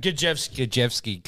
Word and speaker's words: Kajewski: How do you say his name? – Kajewski. – Because, he's also... – The spelling may throw you off.